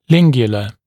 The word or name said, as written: lingula